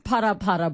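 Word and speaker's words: Phala Phala: Which is pronounced para-para, by the way. Is it pronounced para-para? – I was schooled. I was schooled the other day. Ke para-para, yes. – We thank you for that. I will now refer to it as para-para.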